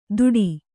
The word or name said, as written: ತುಟಿ